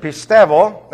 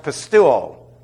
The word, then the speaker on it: pistevo: The verb pistevo, that's P-I-S-T-E-V-O, and by the way, just to clarify that, you'll see it and hear it pronounced pistuo. That is not correct.